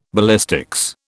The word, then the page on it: ballistics: British English